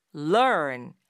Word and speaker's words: learn: R-U-N, 뛰다 라고 할 땐 run, run 이렇게 말하지만 좀 짧고 그리고 R 발음이 혀 끝을 살짝 올려서 당기면서 내는 발음이잖아요. run. 근데 그에 비해서 배우다 라는 뜻의 이 동사 L-E-A-R-N 같은 경우는 일단 시작점 자체가 다르거든요. 혀 끝이 윗니 바로 뒤에서 콕 점을 찍듯이 찍었다가 떼면서 발음하게 돼요. learn 이렇게요.